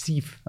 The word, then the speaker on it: Cif: Jif, Cif. I remember the advert. It's Cif.